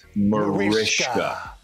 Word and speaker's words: Mariska: But I have to say, in my unending campaign to get everybody to pronounce my wife's name correctly, it's Hungarian and it's Mariska. – Mariska. – Because it's spelled with the S, yeah, Mariska. – But it's Mariska, and we get Mariska actually close, but it's we get Maritza, Martisa, we got everything, but it's Mariska.